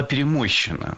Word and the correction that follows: перемощена: Перемощена – это неверно. Правильный вариант – перемощена. Ударение в этой форме падает на окончание «а» – перемощена.